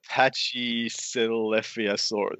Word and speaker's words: Pachycephalosaurus: Pachycephalosaurus.